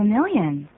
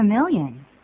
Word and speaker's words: million: Thanks a million. Thanks a million. – Thanks a million. Million 这个念法，我们常常会念成 million，不是 million，而它是这么念的。